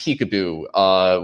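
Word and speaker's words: PCAOB: For some reason, it's pronounced peekaboo when in D.C. I don't know why, but if you don't call it peekaboo, then people think that you're out of the loop.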